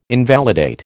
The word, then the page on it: invalidate: American English